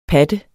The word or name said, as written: PATTERNE